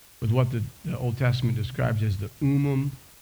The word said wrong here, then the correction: Thummim: I'm going to pronounce it like a Mainer. The Urim and the Thummim, that's it.